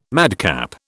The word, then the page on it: madcap: British English